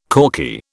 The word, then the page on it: corky: British English